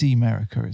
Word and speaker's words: Emerica: Um, and I say that because I've got a pair of Emerica. I think that's how you say it. It is Emerica, isn't it? – I think so.